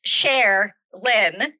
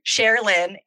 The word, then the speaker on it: Cherlyn: So my name is pronounced Share Lynn, Cher Lynn, and it's spelled just like that, like Cher, the singer with the black frizzy hair, like her, Sonny and Cher, her.